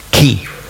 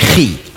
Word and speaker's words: Chi: Chi, das hat man eigentlich ausgesprochen Ki. Nicht Schweizerdeutsch, Chi. Also nicht Chuchichastli in dieser Art, sondern eben Ki.